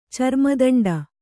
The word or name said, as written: ಚರ್ಮದಂಡ